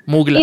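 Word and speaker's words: Mgła: muchos le dicen MGLA, MGLA. Como un besito. – ¿MGLA? Ajá. Marica, yo desde que conozco esa banda nunca he tenido claro cómo se pronuncia. – MGLA.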